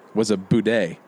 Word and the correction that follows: bidet: Boudet? – A bidet. – Bidet, is that how you say it? – It's a bidet.